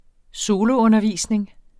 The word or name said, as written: soloundervisning